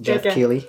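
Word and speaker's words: Geoff Keighley: Jeff Keely.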